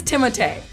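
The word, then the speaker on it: Timothée: So it is Timotei.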